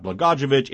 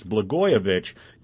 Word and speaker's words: Blagojevich: I mispronounced that Blagojevich. It's Blagojevich.